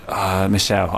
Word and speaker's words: Michel: Is it Michael or Michelle? – It's Michelle. I know it's Michelle.